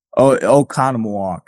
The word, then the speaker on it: Oconomowoc: I'm going to try it. Akanamawak. – Okanamawak. – Oh, dude, I was way off.